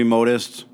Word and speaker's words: Modist: Modest is spelled with an E. For all the listeners out there, it's spelled M-O-D-I-S-T. – Oh, can I just say it? – And Modest, the real word, is spelled M-O-D-E-S-T. – Right, but I still would do the short O on this.